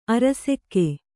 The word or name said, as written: ಅರಸಿಕ